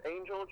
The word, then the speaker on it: Angel: Oh, my name is Angel Trevino. – Angel Trevino. Let me write that down real quick. How do you spell that? – A-N-G-E-L. – It's not G-L-E? – No, it's just A-N-G-E-L, Angel.